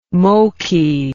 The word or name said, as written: Moqui